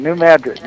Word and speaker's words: New Madrid: Yeti from Penn in the chat room wants to know how you feel about the new Mandarin Fault in Missouri. Am I saying that right, Mandarin? – Yeah, good. You had the right pronunciation. New Madrid, not New Madrid.